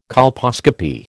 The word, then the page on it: colposcopy: American English